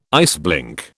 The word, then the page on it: iceblink: British English